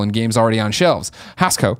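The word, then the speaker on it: Hascoët: Hascoet. I know these French words a lot. I know you don't say the last syllable, the last letter, like the last consonant.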